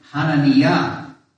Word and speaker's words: Hananiah: If I'm getting this right in Hebrew, more like Hananiah. Right? There is no a in the Hebrew, there's just a. And the accent is on the last vowel. So Hananiah. But anyways,